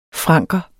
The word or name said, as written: franker